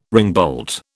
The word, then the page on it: ringbolt: British English